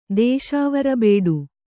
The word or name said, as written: ದೇಶಾವರಬೇಡು